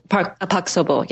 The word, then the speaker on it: Park Seo-bo: Park Seo-bo, yes.